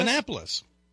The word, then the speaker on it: Annapolis: Is it Annapolis? – Annapolis.